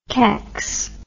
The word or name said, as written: kecks